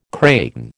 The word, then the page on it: kraken: American English